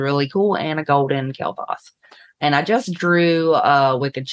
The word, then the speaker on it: Kael'thas: Kael'thas. – Kael'thas.